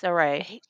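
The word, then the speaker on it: Cirie: How do you pronounce the name? I don't want to say it wrong. Saray. – Saray, yes.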